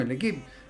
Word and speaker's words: L'Équipe: L'Équipe. Porque no tiene acento en la última.